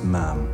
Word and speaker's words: Ma'am: After that, it's Ma'am, rhymes with ham.